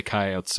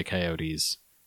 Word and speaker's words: coyotes: If you're confused, in this area, some people say coyotes, some people say coyotes.